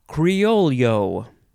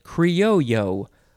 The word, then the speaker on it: criollo: Okay, so the first word in this episode is Criollo. Criollo, or you can say it Criollo without the L sound.